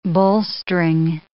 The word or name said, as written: bolstering